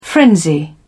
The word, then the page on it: frenzy: British English